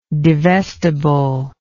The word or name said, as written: divestible